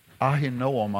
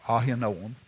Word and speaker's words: Ahinoam: The other one was named Ahinoam, I believe is the way it's pronounced in the Hebrew. Ahinoam.